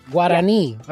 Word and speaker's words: Guarani: And Guarani. – Guarani. – I hope I'm saying that right. Guarani. Yeah. Okay, so yes. The emphasis is I at the end.